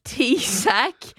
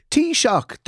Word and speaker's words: Taoiseach: but it said Taoiseach is pronounced like T-sack. I was like, It's a book. Taoiseach, the shock you get from a good cup of tea. Shock.